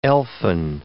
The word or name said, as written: elfin